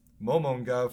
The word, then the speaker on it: Momonga: That's the main guy's Momonga. – Character. – Yeah, that's the main guy. – Momonga. – So, in terms of those, I voted for my boy Momonga from Overlord 3 because I don't see anyone ever being able to be a worst, best protagonist from that.